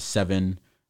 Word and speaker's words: Sevyn: Seven. It's spelled SEVYN, featuring Chris Brown and ASAP Ferg.